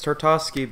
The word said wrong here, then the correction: Tartakovsky: baby. I think that's how you pronounce his name. – I think so. It's Genndy Tartakovsky. Genndy Tartakovsky.